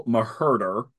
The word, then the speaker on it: Maherder: I think you guys were heading in that direction, but Ma Mabel Maherder, the H is silent,